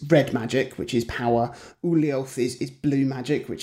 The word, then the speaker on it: Ulyaoth: Either there, there's Chattur'gha, Ulyaoth, and Xel'lotath.